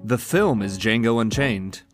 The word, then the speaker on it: Django: And this week we are looking at the 2012 film Django Unchained. – The D is silent.